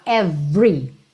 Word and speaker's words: every: Então, lembra que por padrão, esse E do meio do every não é pronunciado. Você fala every, every.